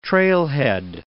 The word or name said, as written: trailhead